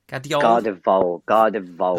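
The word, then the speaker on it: Gvardiol: Is it Gardevol? – Gardevol. – There's a random V just stuck in there.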